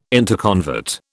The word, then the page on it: interconvert: British English